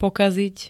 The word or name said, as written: pokaziť